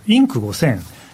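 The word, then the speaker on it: Inc. 5000: 実はですね先週ちょっと私事でお休みいただきましたのでですね先々週ちょっと話題にしていたアメリカの急成長企業ランキングインク 5000、 INC5000 と書いてインク 5000 と読むんですけど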